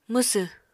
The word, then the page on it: mwsw: Apatani